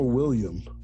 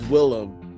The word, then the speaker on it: Willem: Like, is it Willem or William? – It's Willem. Willem. Or it's W-I-L-L-A-M. Yeah, that's how you say it.